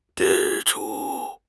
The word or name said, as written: 支出